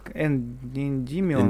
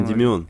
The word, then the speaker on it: Эндимион: Ты говоришь про Гиперион и переход э, к Эндименю. – Да. – Как Эндимион. – Эндимион. Эндимион, правильно, ударение, да.